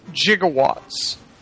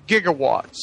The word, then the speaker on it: gigawatts: But this is also where we start the whole gigawatt thing, where it's, in the script, it's spelled J-I-G-O, Watts. And it's because, I guess, Bob Gale and Zemeckis talked to somebody who they thought was a scientist and pronounced it gigawatts. – Uh-huh. – And it's actually gigawatts.